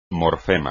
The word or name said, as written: morfema